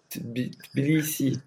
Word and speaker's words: Tbilisi: Je vais essayer de prononcer Tbilisi, TBI. Ah ouais, c'est ça.